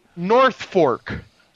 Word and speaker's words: North Fork: But, Norfolk. How do you say that movie? – North Fork. – North Fork or North Folk? – No, North Fork. – So it's Norfolk. – F-O-R-K.